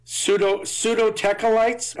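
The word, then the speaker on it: pseudotachylite: Pseudo-tachylites? How do you say that? – Pseudo-tachylite. Terrible word for a wonderful rock.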